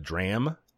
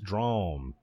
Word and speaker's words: DRAM: I was erroneously pronouncing uh, the the artist's name as uh, uh, Dram, because it's spelled D R A M, but apparently it's pronounced Drom, like uh, the word drawn, but uh, an M at the end instead of an N.